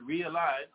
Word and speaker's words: realign: So when I spell the word realign, R-E-A-L-I-G-N, realign. There's a silent letter in it. Where's the G? You don't hear that G, right?